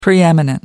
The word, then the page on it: preeminent: British English